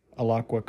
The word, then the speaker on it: Alaqua: Okay, so Alaqua, L-A-L-A-Q-U-A, Alaqua Cox. Okay, yeah, so I'm pretty sure I got her name right. I thought it was cool that the Alaqua Cox actually is all those things.